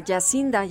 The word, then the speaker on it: Jacinda: Jacinda o Yacinda. Yacinda ya verificamos.